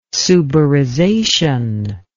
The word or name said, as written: suberization